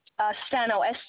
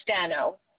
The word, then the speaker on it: Stano: Stano, Stano, and my zip code is 33428.